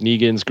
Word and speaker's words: Negan: It's Negan.